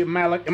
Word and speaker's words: Malick: We are talking with Jay Malick.